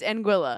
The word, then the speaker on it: Anguilla: Today, 264 takes us to the British territory of Anguilla. Anguilla. Anguilla? How do I... – The island? – Yes. – It's Anguilla.